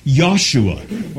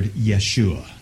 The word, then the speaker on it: Yeshua: Very few languages have that same soft J sound that we have in English. So very few actually would pronounce it Joshua, but it's going to be Yahshua, or perhaps you have heard Yeshua.